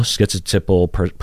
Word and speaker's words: schizotypal: So the first syllable is skit versus skit or ski. So you will hear all three of the, so you have that first syllable, right? Some people pronounce it skit, like schizotypal. You hear that T in there, schizotypal, or ski without a T sound, so schizotypal.